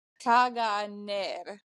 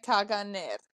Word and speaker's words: Caganer: But it's spelled, and when you Google it, it's Caganer, right? Caganer. So C-A-G-A-N-E-R.